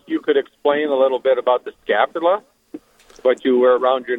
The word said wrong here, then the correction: scapular: is that, did I pronounce it right? – Yep, yes. Scapular. Yep.